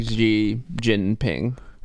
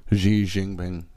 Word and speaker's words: Xi Jinping: I would say like Xi Jinping. – Xi Jinping.